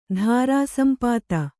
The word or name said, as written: ಧಾರಾಸಂಪಾತ